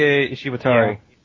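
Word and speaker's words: Daisuke Ishiwatari: Daisuke Ishibatari? Is that how you say his name? – Daisuke Ishibatari.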